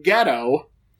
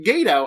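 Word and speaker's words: Gedo: It's Chris Jericho versus Gato, whom they entirely mispronounced his name the entire match, calling him Ghetto. And even when they asked for the correct pronunciation, Mike Tanay told them incorrectly, Ghetto. It's pronounced Gato, as any New Japan fan knows.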